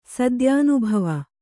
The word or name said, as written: ಸದ್ಯಾನುಭವ